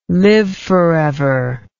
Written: live forever

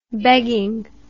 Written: beggingly

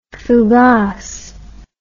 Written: fougasse

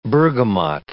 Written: bergamot

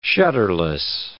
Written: shutterless

